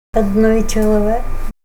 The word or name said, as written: adnoy chalawek